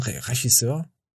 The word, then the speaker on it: Regisseur: Wie wird ein Regisseur? Wie wird das ausgesprochen? Regie? – Regisseur. – Regisseur.